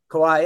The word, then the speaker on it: Kauai: Kauai? Kauai? – Kauai, yeah.